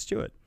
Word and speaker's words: Stuart: My name's Stuart. It's mostly that review it rhymes with Stuart. – Yeah, it's true. – That's true. Speaking of which, that is my name. My name's Stuart.